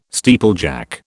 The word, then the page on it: steeplejack: British English